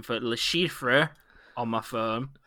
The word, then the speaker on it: Le Chiffre: There is an R in it, but they don't say it in film. – C-H-I-F-F-R-E. Le Chiffre.